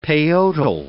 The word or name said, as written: peyotl